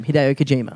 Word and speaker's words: Hideo Kojima: Hideo Kojima.